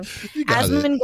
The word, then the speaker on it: Asmongold: Well, interesting development, and you guys said his name funny earlier, so now I'm scared I'm gonna say it wrong. Asmongold. Gold. – You got it.